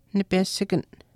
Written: nipesikan

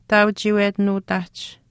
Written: taauchiiwetinuuhtaahch

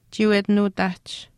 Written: chiiwetinuutaahch

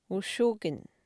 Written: ushukin

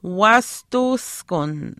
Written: waastuwiskun

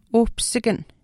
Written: uhpisikan

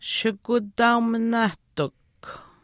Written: shikutaauminaahtikw

